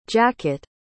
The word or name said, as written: jacot